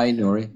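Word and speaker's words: Aït-Nouri: But in the bench, we have Dunk, Mitrovic, and Aitnori. I hope I'm pronouncing it correctly. – Yeah, Aitnori.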